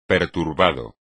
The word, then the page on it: perturbado: espagnol